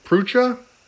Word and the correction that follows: Prucha: Prize, is that right? – I think it's Pruka, but Pruka.